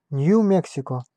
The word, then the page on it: New Mexico: /njuː mɛk.sɪ.kəʊ/; /nu ˈmɛk.si.koʊ/; /nju ˈmɛk.si.koʊ/